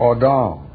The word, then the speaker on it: Adam: It could be Adim, could be Odom, but orally through the centuries it was passed on, and when vowel points were added, Adam became the accepted pronunciation.